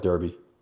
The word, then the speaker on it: Derby: Why is it pronounced Darby, not Derby? – That's an English thing.